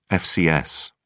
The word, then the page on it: fcs: British English